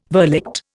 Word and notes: verligte: Indian

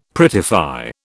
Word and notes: prettify: British English

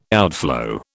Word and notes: outflow: British English

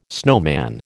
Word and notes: snowman: American English

